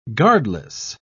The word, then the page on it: guardless: British English